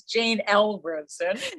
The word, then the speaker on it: Jane L. Rosen: It's Jane L. Rosen.